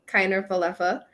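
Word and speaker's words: Kiner-Falefa: And I learned how to pronounce his name. It's Isaiah Kiner Falefa.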